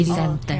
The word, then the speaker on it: presenter: Jadi penekanannya ada pada di second syllable-nya ya, mm-hmm. di zen-nya itu. – Di zen. Oke. Okay. – Presenter.